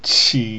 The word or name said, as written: qi